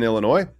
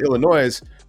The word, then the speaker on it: Illinois: If you want to actually be in Illinois, you don't pronounce the S. But if you call it Illinois, we just think you're not from there.